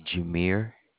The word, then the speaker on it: Jameire: Your name again is Jimmy? – No, ma'am. It's Jameer. – Jameer. How do you spell it? – That is J-A-M-E-I-R-E. – I-R-E.